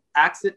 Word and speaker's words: AXIT: So ACSID is spelled AXIT, the ACSID movement. It's exactly how it's spelled.